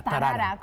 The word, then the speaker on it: Tarara: Tarara, como el personaje popular de la canción folclórica.